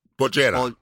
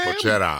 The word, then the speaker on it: bollera: And it's the word bojera. – Bojera. – Bojera. How do you spell that? – Bojera. – B-O-L-L-E-R-A.